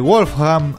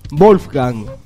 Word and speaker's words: Wolfgang: Es Wolfgang, no Wolfgang. – Wolfgang. – No, Wolfgang. – Boludo. – No, porque la W no se pronuncia en alemán.